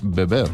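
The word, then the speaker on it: Veber: Es una famosa comedia escrita ya por el año 1993 por el dramaturgo francés Francis Weber. Creo que lo he dicho bien. Eusebio Salinas es el director del grupo Teatro Berea. Eusebio, buenas tardes. Bienvenido. – Buenas tardes. Sí, lo has dicho bien, perfectamente.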